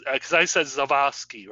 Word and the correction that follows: Zaslavsky: right? – Zaslavsky. – That's how you say that? – Just like it's spelled.